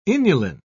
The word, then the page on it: inulin: British English